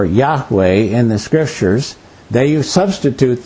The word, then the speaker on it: Yahweh: We pronounce that Yahweh. We're not sure exactly how it's pronounced because when the Bible was written originally, they had no vowels. But we think it's Yahweh.